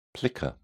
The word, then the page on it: plica: British English